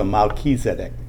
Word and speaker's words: Melchizedek: It's Malki, which is Melech, king, and Zedek, which means righteousness. So Melchizedek is how they say it.